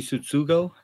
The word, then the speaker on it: Tsutsugo: Yeah, Yoshi Tsutsugo.